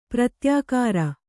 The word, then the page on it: ಪ್ರತ್ಯಾಕಾರ: pratyākāra